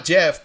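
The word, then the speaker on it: Geoff: And he embraced the meme, porque pues Jeff Keighley, pues, él se llama Jeff, pero él es canadiense, so se escribe G-E-O-F-F, y Jeff Goldblum se lo vaciló por el nombre, dijo, ¿Jeff?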